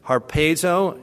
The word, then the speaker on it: harpazo: The rapture teaching then comes from the Greek word that appears here, harpazo, H-A-R-P-A-Z, long O, that's used here in 1 Thessalonians 4, verse 17.